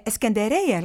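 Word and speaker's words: Εσκεντερέια (إسكندرية): Εσκεντερέια λέγεται η Αλεξάνδρεια και έχει γραφτεί λοιπόν το κομμάτι, λέει ότι Εσκεντερέια, Εσκεντερέια, κουλάχα χανέια, ομαναρχάμαν.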